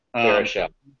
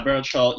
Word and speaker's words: Baruchel: Baruchel. – Yeah, Baruchel.